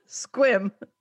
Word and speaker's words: Sequim: Squim. – Sequim. – Sequim. Squim. – Squim. – It's Sequim. Pronounced Squim.